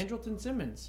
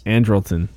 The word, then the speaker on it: Andrelton: Andrelton Simmons. – Andrelton.